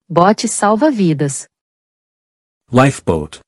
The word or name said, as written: Bote salva-vidas